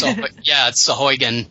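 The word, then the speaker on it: Sahuagin: Sahugin. Although we did finally find out that it was supposed to be like Sheboygan. – It's Sahugin.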